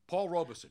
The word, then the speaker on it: Robeson: Paul Robeson.